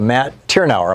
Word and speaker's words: Tyrnauer: And Matt Tyrnauer, am I saying your name right, Matt? – You got it.